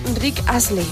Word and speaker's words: Rick Astley: ¿De Rick Astley.